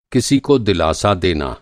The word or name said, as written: दिलासा